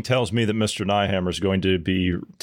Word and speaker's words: Nehammer: Karl Neth- Nethammer. – Nehammer. Yeah. – Nehammer. That's it.